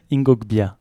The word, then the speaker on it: Ingo Bia: Je l'ai bien prononcé ? – Ingo Bia. – Super.